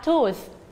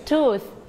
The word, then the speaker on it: tooth: ลงท้ายด้วย TH ออกเสียงให้ชัดนะคะ tooth tooth